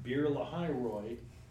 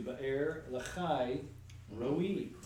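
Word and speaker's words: Ba'er Lahai Roi (באר לחי ראי): And it turns out that Bir Lahai Roy, as we've heard in church, is actually Ba'er Lahai Roi.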